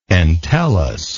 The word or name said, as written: entellus